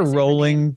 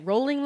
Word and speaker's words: Rowling: I hear rolling, but I don't know. – Rolling, rolling. It's I think it's rolling like bowling.